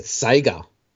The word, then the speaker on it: Sega: and she said Sega. – Oh. – So that's it. That's how you say it.